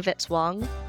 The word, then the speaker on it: Vitzwong: Music by Kevin Vitzwong, rhymes with hit song.